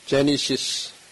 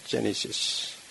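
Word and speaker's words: Genesis: 그래서 그냥 제니시스, 발음만 조금 다르게 됐고 내용은 문자는 같습니다. 제니시스.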